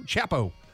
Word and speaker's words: Chapo: I love that you just called him Chapo. – Whatever. Whatever. It's pronounced and spelled the same, C-H-A-P-O, Chapo.